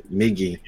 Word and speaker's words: Migi: How do you say Migi or Migi? – Migi. Migi. Not Migi, but Parasite's really great too.